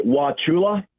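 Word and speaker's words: Wauchula: Help me with the pronunciation of your town in Florida. – That would be Wachula. – Wachula.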